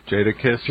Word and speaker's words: Jadakiss: And did I say it right? – Jadakiss. – Jadakiss. Okay, I'm sorry.